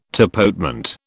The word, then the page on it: tapotement: British English